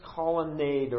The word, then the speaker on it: colonnade: They're in Solomon's colonnade, or how do you pronounce it? – Colonnade.